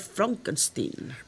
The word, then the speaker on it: Frankenstein: Gene Wilder interpretó a Frederick Frankenstein, pronunciado Frankenstein.